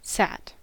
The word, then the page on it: sat: American English